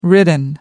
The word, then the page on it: ridden: American English